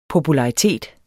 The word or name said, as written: popularitet